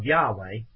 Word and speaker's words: Yahweh: Now, you see, people have taken the name of Yahweh, that's the way it's pronounced, 7,000 times approximately, and we got preachers on the air that's making fun of the name.